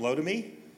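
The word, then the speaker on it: Lotame: We have Ryan Reed from Lotami. – Yes, you pronounced that right.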